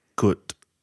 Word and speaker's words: Gut: And I think the pronunciation is gut, is G-U-T.